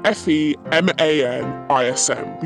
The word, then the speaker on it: FeMANism: And that's spelled F E M A N I S M. We've just put the word man in the middle of feminism. – FeMANism is a Podmonkey original written and performed by Sam Martin and me, Amy Hoggart.